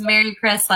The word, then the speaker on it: Maricris: So it's Merry Chris, like Merry Christmas, and last name's LePay.